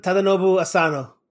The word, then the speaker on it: Tadanobu Asano: The other guy, Tadanobu. Am I going to say that right? – Tadanobu Asano. – Tadanobu. – Okay, Tadanobu.